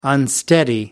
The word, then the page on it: unsteady: American English